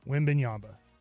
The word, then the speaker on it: Wembanyama: Wembanyama. Did I say it right that time? Or I got really. – You got it.